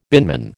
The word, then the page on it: binman: American English